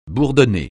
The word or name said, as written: bourdonner